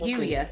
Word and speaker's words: Youier: You hire. Yeah. What does it mean, and why is it so important? – I love the way you said it. The way that I pronounce it is Yuya.